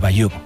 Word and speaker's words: Bayeux: El tapiz de Bayeux.